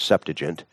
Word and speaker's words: Septuagint: It's spelled Septuagint, but if you want to be truly scholarly, it's Septuagint.